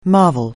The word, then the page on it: marvel: anglais